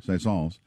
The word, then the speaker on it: Saint-Saëns: Saint-Saëns.